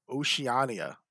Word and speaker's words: Oceania: How do you actually say that word? – Oceania. – Okay, there we go.